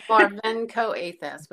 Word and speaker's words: Marvenko-Athas: It's Marvenko-Athas, but that's fine.